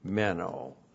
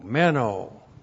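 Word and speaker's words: Meno (μένω): Meno. M-E-N long O. Meno.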